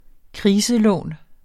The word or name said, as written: kriselån